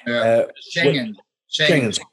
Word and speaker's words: Schengen: So before the Schengen treaty, I pronounced that wrong, haven't I? – Schengen. – Thank you. It didn't sound right to me.